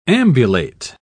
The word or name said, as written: ambulate